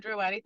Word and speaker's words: Durowade: So I am, like you said, Dr. Taiwo Durowade.